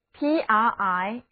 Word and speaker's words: primitive: Primitive， P-R-I-M-I-T-I-V-E， Primitive，这里的三个字母 I 都读作短的 E。